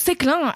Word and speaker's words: Séclin: Mais eh, Marion C'est Séclin, qui se prononce Céclin avec un E sur le E.